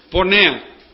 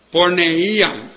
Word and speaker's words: porneía: Pornea. P-O-R-N-E-I-A. Porneía, pero es más fácil decir pornea, porque en griego así se pronuncia.